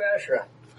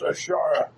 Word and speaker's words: Ashara: Are you Ashra? – It's Ashara. – Ashara, and yes, I am. – You spell it Ashra.